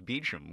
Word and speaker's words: Beauchamp: Oh, actually, it's pronounced Beecham, which is weird. – Beecham. – Because okay. – Because it's spelled like the French Beauchamp, so you think.